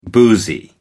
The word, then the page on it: boozy: American English